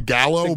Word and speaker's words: Gallo: It's technically Pico de Gallo, but the whole joke, my name's spelled like Gallo.